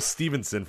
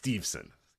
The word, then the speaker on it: Steveson: But I say Gable Stevenson for an entire show, and nobody cares. But uh, yeah, it's Steveson evil.